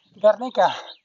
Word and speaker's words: Guernica: Anyhow, and I was saying it wrong, because his words are just weird in Basque, you know, in euskera. And she was like, it's the most famous small town in Spain, Guernica.